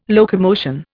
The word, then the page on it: locomotion: Indian